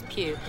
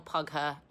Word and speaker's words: Pugh: You say it like that, Florence Pugh. It's like Pugh, but with a P. No, it's not pug her, and no, it's not poo.